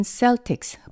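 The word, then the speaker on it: Celtics: 미국 발음으로는 셀틱스, 버스턴 셀틱스라고 발음하지만요.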